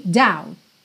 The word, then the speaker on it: doubt: Essa palavra doubt é interessante porque ela tem BT no final, mas o B não é pronunciado e o T é aquela consoante oclusiva que pode aparecer mais, menos ou nada, frequentemente aparece nada, e aí você tem doubt, doubt.